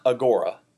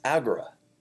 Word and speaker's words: agora: It's not agora. It's agra. Agra. The accent is on the first syllable, not the middle syllable.